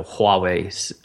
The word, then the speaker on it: Huawei: Because it's like a Hua, right? – Yeah, yep. – The 6P, because I'm, you know, I probably wouldn't be one to buy a Huawei phone, but I might, actually.